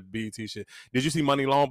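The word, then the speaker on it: Muni Long: It's Money Long.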